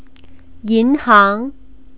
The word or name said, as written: yinhang